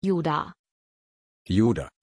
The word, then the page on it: judah: German